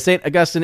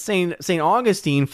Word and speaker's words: St. Augustine: Because it's St. Augustine, Florida, but the saint is St. Augustine, is the proper pronunciation if you're amongst the academic circles.